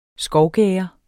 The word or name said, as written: skovgærde